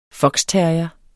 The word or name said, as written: foxterrier